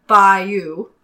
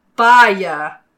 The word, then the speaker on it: Bayeux: Americans say Bayeux. – Okay. – British say Bayeux. – Uh-huh. Uh-huh. – And French, since it is a French word, it's more like Bayeux.